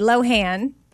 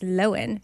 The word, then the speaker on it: Lohan: So we say Lohan, but yeah, it's Lohan.